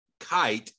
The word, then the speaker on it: Kight: It's actually, the town was named after a guy named Shadrick Kite, and it's spelled K-I-G-H-T when he donated the land to build the town.